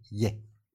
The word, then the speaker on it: Ye: Kanye West se ha cambiado legalmente de nombre por Ye. – Ye. – Ye, que es la última sílaba de Kanye.